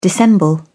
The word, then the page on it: dissemble: British English